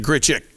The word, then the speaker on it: Grichuk: His name ends with UK, but he pronounces it Grichuk. So you don't say Grichuk if you didn't know that.